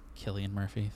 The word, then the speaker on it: Cillian Murphy: It was just really incredible, and I'm a sucker for Cillian Murphy. – Did you? It's Cillian Murphy. Thank you very Cillian. – Much. – I always say it wrong.